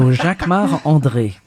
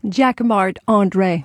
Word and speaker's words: Jacquemart-André: Jacquemart-André. You say it like a Frenchman. – Because I'm French. So Jacquemart-André. – And say it so we can understand it. – Jacquemart-André.